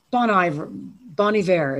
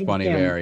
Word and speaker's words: Bon Iver: Bon Iver, is that how you pronounce it? – I think it's Bon Iver, yes.